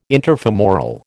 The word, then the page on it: interfemoral: American English